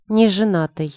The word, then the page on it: nezenatyj: Russian